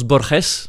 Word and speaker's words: Borges: Alors, ça se prononce Borges en réalité.